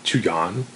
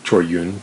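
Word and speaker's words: Chor Yuen: And I saw a film by Chu Yan, or Choi Yun, to use his Cantonese pronunciation, who is one of the stalwart Hong Kong or Shaw Brothers directors.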